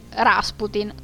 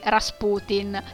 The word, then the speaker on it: Rasputin: E qui, esattamente come feci due anni fa, nella puntata dedicata a Nicola II di Russia, l'ultimo degli zar, vi dico che io l'ho sempre pronunciato così, Rasputin, e so che qualcuno lo pronuncia anche Rasputin, e questa dovrebbe essere la pronuncia corretta in realtà, però vabbè, io continuerò a chiamarlo come ho sempre fatto.